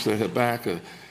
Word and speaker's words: Habakkuk: And please, if my pronunciation is different, that's how we say it in the hood. We say Habakkuk.